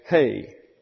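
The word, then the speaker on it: hay (ἡ): And this is an eta here, which is an E, but a long E, pronounced in the Greek as hay, like something cows eat.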